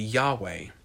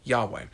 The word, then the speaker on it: Yahweh: And so many modern scholars believe that the proper pronunciation of God's name would be Yahweh. Yahweh. And so Y-H-W-H